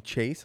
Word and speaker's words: Chase: I'm like, yo, they've been saying his name wrong this whole time. And you're like, what do you mean? I was like, Jace. You're like, you mean Chase?